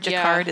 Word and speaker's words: Jacquard: Jacquard, yeah. – Is that how you say it? – Yeah, okay.